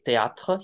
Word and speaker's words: théâtre: And he's like, but then he made me say théâtre. He was in theater, he was in a theater group. And théâtre Yeah. – harder, two syllables, and then TRs. You're moving from the tip of your tongue to the back of your throat. It's harder, right?